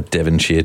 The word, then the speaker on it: Devonshire: Man, I love a Devonshire tea. – Did I say that wrong? Devon... – No, no, no, you said it right.